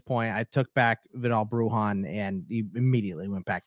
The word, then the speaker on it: Brujan: Yeah, Bruon had a lot of hype.